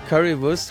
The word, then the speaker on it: Currywurst: Currywurst. – Currywurst.